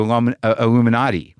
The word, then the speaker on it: Illuminati: Some people got hung up with my pronunciation of the Illuminati as opposed to Illuminati. But, you know, look, sometimes I stylize.